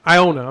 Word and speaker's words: Iona: You said Inola? – Iona. I'm sorry. Iona. – Iona. – Not Inola. Iona.